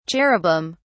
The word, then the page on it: cherubim: British English